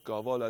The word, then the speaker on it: qaval: In the days leading up to Nowruz in the streets of Azerbaijan, a group danced with qaval, or qaval as is the correct pronunciation, and wooden goats.